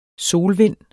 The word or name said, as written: solvind